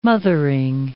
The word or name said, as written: mothering